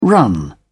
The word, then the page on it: run on: British English